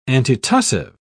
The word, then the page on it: antitussive: British English